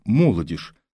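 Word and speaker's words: молодёжь: Тут уж никак не произнесешь «молодежь». Этому мешает и ритм песни, и рифма. Песню пели многие, и правильное произношение прививается само собою, хотя, возможно, поэт совершенно не думал о том, что его произведение выполнит наряду с другими и эту полезную работу.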